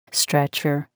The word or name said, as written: stretcher